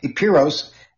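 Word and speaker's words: Epeiros: It's E-P-E-I-R-O-S, Epiros, and the other one is Occidentalis, O-C-C-I-D-E-N-T-A-L-I-S.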